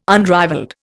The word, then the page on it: unrivalled: Indian